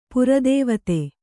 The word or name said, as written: ಪರದೇವತೆ